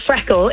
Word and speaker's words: FRKL: Freckle, it's called, which is spelt, I mean, I say it's spelt Freckle without the vowels, but there's also a C missing. So it's spelled, I wasn't brilliant at school. Freckle, so it's spelt F-R-K-L, and it's, a jewellery business.